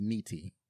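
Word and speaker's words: Nitti: Billy Drago played Frank Netti, or I'm sorry, it's probably pronounced Neeti.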